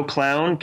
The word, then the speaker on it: Klown: That's Joe Clown, KLOWN or KLOUN.